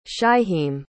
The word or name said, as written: shyheim